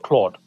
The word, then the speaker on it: Clawed: It's spelled C-L-A-W-E-D, Claude, like a claw. – Yeah. – But obviously it's a play on the word Claude, like, you know, from Anthropic.